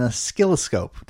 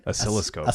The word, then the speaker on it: oscilloscope: oscilloscope. Did I say that right? – Oscilloscope.